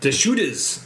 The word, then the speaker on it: Deschutes: Deschutes. – Deschutes Rendition, which is featuring Centennial hops this year. I was quite optimistic for this one because Deschutes is a top-tier operation, and Centennial is the dominant hop in Bell's Two Hearted.